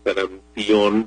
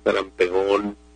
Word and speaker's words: sarampión: Son las mismas personas que en lugar de sarampión dicen sarampeón, o espurio, con E, en vez de espurio.